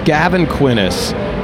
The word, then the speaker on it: Gavin Quinnies: This is Gavin Quinnies, and did I say it right, Gavin? – That's right.